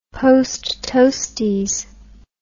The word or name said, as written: posttoasties